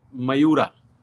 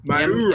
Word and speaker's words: Mayura: So just to let our viewers know and those listening on the audio, I've got your M-Cuba, and then how do I pronounce the name of it? – M-Cuba is a line, and this blend that we have released, it's known as Mayura. – Mayura. – M-A-Y, Mayura.